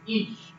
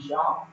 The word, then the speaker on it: Isha: The Hebrew word for man is ish. The Hebrew word for woman, I'll call you see. It's Isha.